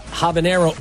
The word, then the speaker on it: habanero: Is that how you say it? Habanero oil? – Habanero, yeah.